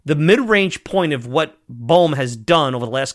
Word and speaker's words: Bohm: Because when you look at an Alec Bohm, and I always say his name wrong. Let me make sure. Bohm.